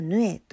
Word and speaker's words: 鵺: 会長と書いてそのままヌエと読ますこともありますね。